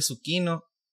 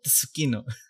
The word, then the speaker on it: Tsukino: Greffy, no sé si se pronuncia Tsukino o Tsukino. – No, un zucchino, así como que fuera solo ese.